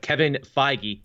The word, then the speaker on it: Feige: But Kevin Feige, see, I said it right that time. I didn't call him Kevin Feige.